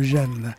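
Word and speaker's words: Jamme: Est-ce que je prononce bien le Jam? – Tout à fait. – Donc, parce que j'ai un peu écorché votre nom pendant l'émission et aussi le nom de Jam, le prononçant avec un vague accent anglo-saxon tout à fait déplacé.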